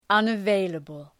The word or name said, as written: unavailable